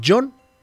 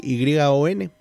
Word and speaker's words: yon: Porque en japonés el 4 se dice shi. Y el significado de la palabra shi es muerte y se pronuncia igual que el número 4. Así que para remediarlo, la pronunciación eh, del número 4 cambió a John. Así, Y-O-N.